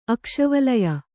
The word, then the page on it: ಆಗಸವಳಯ: āgasavaḷaya